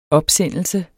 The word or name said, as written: opsendelse